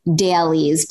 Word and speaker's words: Daleez: It's called dailies, but it's spelled D-A-L-E-E-Z.